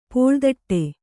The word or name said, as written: ಪುಳಿದೊಡೆ